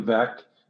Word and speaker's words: Veeck: And one of the names on your ownership list and team setup is it rhymes with wreck. And we know Bill Veeck is one of your all-time heroes.